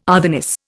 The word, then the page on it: otherness: Indian